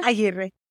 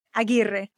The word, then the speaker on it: Aguirre: Maria Aguirre. – Oh, okay. – It's the rolling of the R's.